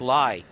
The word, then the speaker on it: Lai: It's L-A-I. I think it's pronounced Lai.